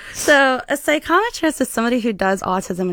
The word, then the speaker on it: psychometrist: And one of your titles is psychometrist.